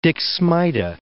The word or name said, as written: Dixmuide